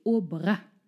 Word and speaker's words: Aubrac: Heute sprechen wir über die wunderbare Lucie Aubrac. Liebe Katrin, wer war sie? – Übrigens, hinten das C spricht man mit.